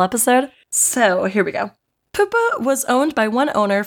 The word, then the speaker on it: Pupa: Well, it's an Italian haunted doll, so I think it's like Poopa. – Ah, got it. – Like, it's spelled P-U-P-A.